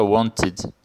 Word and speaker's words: wanted: Então, é, então ele é, a pronúncia fica wanted e não wanted. Wanted, como se tivesse um i junto ali na pronúncia.